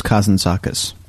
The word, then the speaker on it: Kazantzakis: Nikos Kazantzakis.